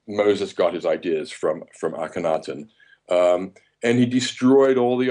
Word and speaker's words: Akhenaten: Is, I think you pronounce it Aten? Is it Aten? The Egyptian Akhenaten. Yeah. – Yeah, Akhenaten. That's it.